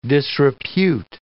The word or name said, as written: disrepute